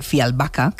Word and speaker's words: Fjällbacka: Es verdad que sus novelas parecen guiones, Pues resulta que los crímenes de Fjällbacka,